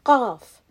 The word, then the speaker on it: Qaf: And that rhymes with qaf. So every time I say 50, you should think half and then qaf.